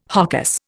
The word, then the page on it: hocus: Indian